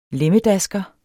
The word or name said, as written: LEMMEDASKERE